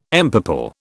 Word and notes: empurple: British English

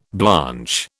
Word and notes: blanch: British English

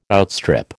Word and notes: outstrip: American English

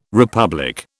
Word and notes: republic: British English

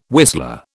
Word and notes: whistler: British English

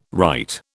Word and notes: right: British English

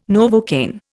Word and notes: Novocaine: Indian

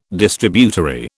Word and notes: Distributary: British English

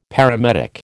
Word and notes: paramedic: American English

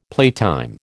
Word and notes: playtime: American English

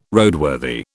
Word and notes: Roadworthy: British English

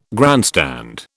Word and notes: grandstand: British English

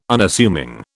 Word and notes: Unassuming: British English

